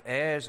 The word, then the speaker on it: heir: heirs of salvation, and maybe what includes it all, heirs of God and joint heirs with Christ. Now in England, when we say heir, we don't pronounce the H.